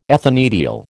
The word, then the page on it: ethanediol: American English